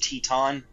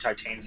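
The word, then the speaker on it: Titane: You know, it's a little over a week, but like 2 weeks ago, I saw Lamb and I guess it's pronounced Teton, but Titan sounds cool.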